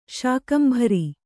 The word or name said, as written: ಶಾಕಂಭರಿ